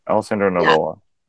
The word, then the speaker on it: Alessandro Nivola: Is it Alessandro Nivola? I'm getting his name wrong. – No, that's right. Alessandro Nivola.